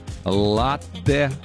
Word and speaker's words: латте: Вот зашли вы в какую-нибудь кофейню и спрашиваете, просите приготовить латте. Или все-таки латте? Куда и как правильно ставить ударение? Ну-ка. – На первый сок. Латте.